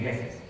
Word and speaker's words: Jefe: So instead, when I took Spanish in school years and years ago, was pronounced the J, it's like an H, and so it's pronounced the teacher. She said, okay, your Spanish name will be Jefe.